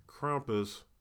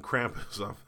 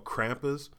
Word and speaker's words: Krampus: Oh, this weekend we have Krampus that's coming out, which, Krampus, I mean Krampus, I was saying it wrong, I'm sorry, it's called Krampus.